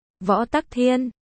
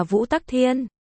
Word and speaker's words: Võ Tắc Thiên: Võ Tắc Thiên, cũng được đọc là Vũ Tắc Thiên,